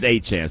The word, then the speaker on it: Etienne: I just know that how you say it is HN.